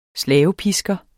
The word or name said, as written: Slavepisker